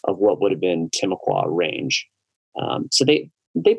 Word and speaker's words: Timucua: Yeah, so we say Timucua, but of course they're totally extinct. So it's hard to say how it's actually pronounced. I've never found someone to correct me on saying Timucua, but that's how we say it here.